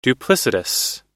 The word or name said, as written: duplicitous